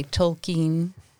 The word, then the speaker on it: Tolkien: Whereas he would say Tolkien. – That's the proper pronunciation of it.